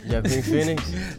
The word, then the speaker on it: Joaquin Phoenix: Joaquin Phoenix.